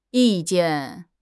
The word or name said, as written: yi4 jian4